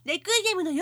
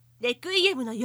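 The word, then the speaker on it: 鎮魂歌: いとこには、レクイエムの陽子もいます。チンコンカと書いて、レクイエムの陽子でございます。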